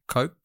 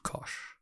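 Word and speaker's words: Koch: Well, I think one of them is, his name's David Coke, mm-hmm. – which is spelt exactly the same as David Kosh.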